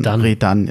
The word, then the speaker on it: Redan: Der Nachname ist ja fast noch das Einfachste, wobei da wohl, wenn ich Damenwahl glauben darf, und das tue ich natürlich, die Betonung eigentlich auf der zweiten Silbe von Redan ist.